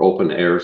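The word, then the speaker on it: openEHR: But combine that with information modeling, like has been around since the 1990s, called one of the most significant of which is Open EHR, or Open Air sometimes it's pronounced.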